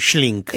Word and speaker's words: Schlink: ¿Está bien pronunciado? – Sí, Schlink.